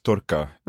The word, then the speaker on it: tarka: So you mentioned several times turka. Mm. Did I pronounce that right? – Yes.